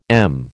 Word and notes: m: American English

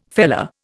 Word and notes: feller: Indian

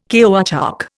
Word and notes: caoutchouc: Indian

